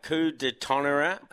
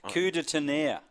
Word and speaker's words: Coup de Tonnerre: Race three, you're riding Coup de Tonnerre. This is the horse. – You butchered that. – Is it? What is it? – Coup de Tonnerre.